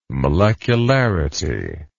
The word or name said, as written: molecularity